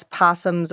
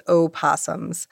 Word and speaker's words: opossum: Okay, and before we move on, I think we do need to discuss possums versus opossums. Would anyone like to tell us, please, what the difference is? – Same animal. You can either pronounce the O or not.